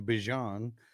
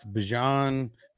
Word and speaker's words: Bijan: Bijan. That's how you pronounce it. That's how he said he wants it. That's how he said he pronounces his name, Bijan. So it's Bijan, Kyren, and Warren.